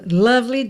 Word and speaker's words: love: Cuidado para não dizer love, nem love, tá? São duas questões aqui que a gente tem que prestar atenção. Nem o O tem som de O, é a, e o E final não é pronunciado. Então, love.